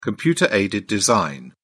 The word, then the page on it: computer-aided design: British English